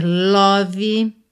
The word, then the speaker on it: love: Cuidado para não dizer love, nem love, tá? São duas questões aqui que a gente tem que prestar atenção. Nem o O tem som de O, é a, e o E final não é pronunciado. Então, love. Uhum.